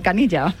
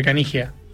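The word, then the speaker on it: Caniggia: Canilla? Uf. ¿Lo digo bien? ¿No es Canilla? – Canilla, diría que nosotros siempre Canigia. En Argentina se pronuncia el italiano, todos los idiomas, como suena.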